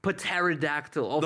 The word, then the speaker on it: pterodactyl: Okay, it's spelled P-H-I-L-O-S-O-R-A-P-T-O-R. – There's no way pterodactyl starts with a P. – On Blasi's Etsy shirt. – Oh, English is fake, nigga. – Come on now. – English is fake. – Wait, what? That doesn't even say pterodactyl. It says pterodactylus. – Me and my boy some good spellers. – It's a silent P.